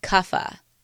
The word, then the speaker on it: Kapha: Vata, which is spelled V-A-T-A, Pitta, P-I-T-T-A, and Kapha, K-A-P-H-A. Vata, Pitta, and Kapha.